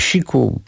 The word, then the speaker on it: SIIQ: A przed nami zespół Siku, zresztą pisze się to zupełnie tak, jakby na pierwszy rzut oka nie chodziło o to, S-I-I-Q, ale czyta się Siku,